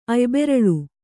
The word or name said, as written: ಅಯ್ಬೆರಳು